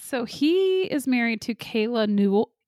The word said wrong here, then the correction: Ewell: not Newell, Ewell, Ewell. I don't know how to say it. E-W-E-L-L, who played in Vampire Diaries, Matt Donovan's sister.